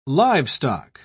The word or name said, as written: livestock